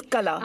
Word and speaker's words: Qala: We watched Qala, which is spelled Q-A-L-A. Mm-hmm. – It's Qala.